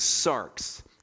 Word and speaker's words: sarx: And the Greek word for flesh is sarx. It's spelled S-A-R-X, sarx.